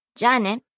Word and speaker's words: じゃあ: Notice the extended pronunciation of the vowel at the end of じゃあ。 – Listen again. – じゃあ。 – This is a long vowel.